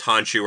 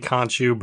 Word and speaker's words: Khonshu: But I'm really excited because I both enjoy Oscar Isaacs and I want to hear aloud definitively whether it's pronounced Honshu or Khonshu. – It's definitely Khonshu, but okay, sure.